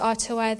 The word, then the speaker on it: Atua: That word, Atua, it is a Māori word. Pronounced Atua, there's two words with two meanings beautiful God.